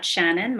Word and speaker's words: Shannyn: You can send me an email at Shannyn. My first name is spelled S-H-A-N-N-Y-N, Shannyn@thecapitalboutique, T-H-E-C-A-P-I-T-A-L-B-O-U-T-I-Q-U-E.